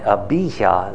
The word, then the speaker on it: Abijah: And so we get to Rehoboam's son, Abijah is how you pronounce it.